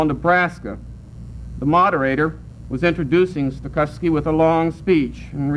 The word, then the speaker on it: Stokowski: I want you all to know that there is no cow in my name. It is pronounced Stokowski."